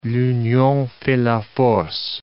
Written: l'union fait la force

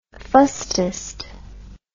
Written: fustest

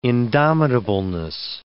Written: indomitableness

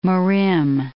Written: Mirim

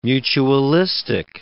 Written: mutualistic

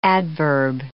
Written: adverb

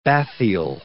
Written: bathyal

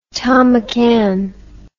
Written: thom mcan

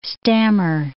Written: stammer

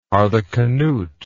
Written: harthacnut

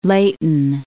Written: Layton

